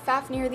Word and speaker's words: Fafnir: Next, we have Fafnir, the Elemental Dragon Guardian of Fire, and this is spelled F-A-F-N-I-R, but is pronounced F-A-F-N-E-E-R, like near, not like Nair, because that's kind of how it's spelled, so we're going to call him Fafnir instead of the way it's like spelled.